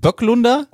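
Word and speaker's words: Böklunder: Böglunder ist... – Heißt das nicht Böcklunder? – Oh, spricht man nicht Böcklunder? – Ja, ich glaube.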